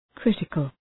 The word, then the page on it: critical: ‘krıtıkəl